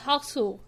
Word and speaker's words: Hausu: So it is House, but they, they pronounce it as Hausu.